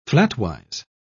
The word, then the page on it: flatwise: British English